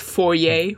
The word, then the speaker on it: foyer: It's, yeah, because it's a French word, and say foyer, which is how it's pronounced in French. Not foyer, foyer.